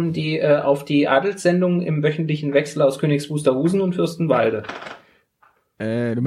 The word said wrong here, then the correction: Königs Wusterhausen: meinst Königs Wusterhausen.